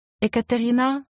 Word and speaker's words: Екатерина: «Екатерина?» С ударением на конечную «а».